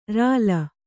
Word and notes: ಱಳ: ṛaḷa

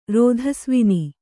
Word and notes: ರೋಧಸ್ವಿನಿ: kannada

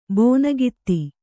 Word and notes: ಬೋನಗಿತ್ತಿ: bōnagitti

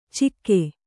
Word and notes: ಚಿಕ್ಕೆ: kannada

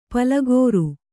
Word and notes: ಪಲುಗಿರಿ: palugiri

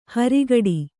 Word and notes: ಹರಿಗಡಿ: kannada

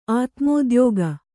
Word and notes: ಆತ್ಮೋದ್ಯೋಗ: atmōdyōga

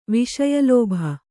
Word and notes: ವಿಷಯಲೋಭ: Kannada

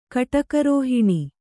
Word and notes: ಕಟುಕರೋಹಿಣಿ: kaṭukarōhiṇi

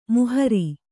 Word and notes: ಮೊಹರೆ: mohare

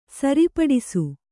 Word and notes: ಸರಿಪಡಿಸು: sari paḍisu

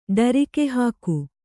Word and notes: ಢರಕಿಹಾಕು: Kannada

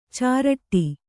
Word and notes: ಚಿರಡಿ: kannada

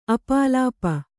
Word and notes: ಅಪಲಾಪ: apalāpa

